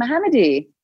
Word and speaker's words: Mohammadi: I'm Kamin Mohammadi.